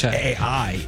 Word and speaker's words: Pichai: The most he did as far as talking AI was make a joke that the last two letters of his name, Pichai, are AI. Sound like AI. – It is Pichai.